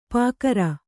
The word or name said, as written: ಪೂಗಾರ